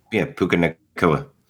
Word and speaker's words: Puka Nacua: Puka Nacua.